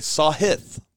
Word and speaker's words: Sahith: Our boy Sahith, by the way. Sahith, not Sahith. – We're learning how to pronounce the name.